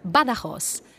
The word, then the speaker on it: Badajoz: It's Maria Badajoz. – How do you spell that? – My first name's Maria. M-A-R-I-A. – And your last name? – Badajoz. That's B-A-D-A-J-O-Z.